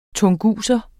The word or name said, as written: tunguser